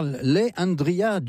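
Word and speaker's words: Le'Andria: Alors, Leandria, ça s'écrit L-E apostrophe et Andrea, est une artiste de gospel encore jeune.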